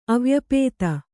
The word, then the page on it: ಅವ್ಯಾಪ್ತಿ: avyapti